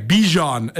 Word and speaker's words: Bijan: Bijan is how you pronounce it.